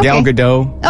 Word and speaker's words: Gal Gadot: Was it Gal Gadot? Did I even say that right? – Yeah, okay. – Gal Gadot.